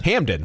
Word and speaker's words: Hamden: Am I saying that correctly? – Hamden, right. – Hamden. Like the Camden connection.